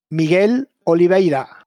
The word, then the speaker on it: Miguel Oliveira: He was a little upset because KTM had told him that Miguel Oliveira would switch to the factory team. – Hang on, wait, can you say his name again? – Miguel Oliveira.